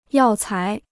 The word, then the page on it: 药材: yào cái